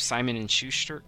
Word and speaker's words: Simon and Schuster: I think basically they're kind of at the mercy of Simon and Schuster? Is that how you pronounce it? Yep, and Domo Arigato.